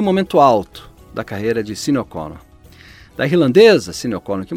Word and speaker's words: Sinéad O'Connor: Os problemas de Sinéad O'Connor com, ou Sinéad O'Connor, de acordo com a pronúncia, com a depressão, já haviam sido revelados, né, e destacados pela imprensa na época.